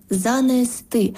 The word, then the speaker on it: занести: Щодо наголосів, то правильно принести, розповісти, занести. Тобто наголос на останній склад «сти».